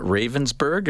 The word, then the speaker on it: Ravensburg: Is that Ravensburg? Is that how yes. – Am I saying that right? – Yes.